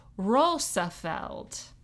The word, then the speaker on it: Roosevelt: Anyway, the closest pronunciation to how it would be said in Dutch is Roosevelt. – Sure. – With a light roll on the R.